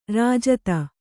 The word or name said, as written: ರಾಜಿತ